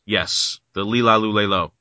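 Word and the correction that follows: La-Li-Lu-Le-Lo: Lali Lulelo.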